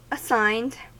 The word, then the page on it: assigned: American English